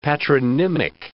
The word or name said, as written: patronymic